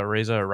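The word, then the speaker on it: Araiza: Yeah, Areza.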